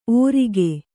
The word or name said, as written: ಓರಿಗೆ